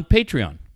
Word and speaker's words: Patreon: Rob Harrington sent me great information on Patron. Am I saying that right? Patron's the tequila. Yeah. – No, you're thinking Patreon.